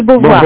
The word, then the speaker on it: Beauvoir: Yes, Simone de Beauvoir, B-E-A-U-V-O-I-R. – Oh, Simone de Beauvoir, Simone de Beauvoir, Beauvoir. – Yes. – Yeah. Yes, thank you. – Yes. – Thank you for saying it right.